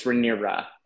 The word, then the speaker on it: Rhaenyra: Rhaenyra or Rhaenyra? – I think it's Rhaenyra.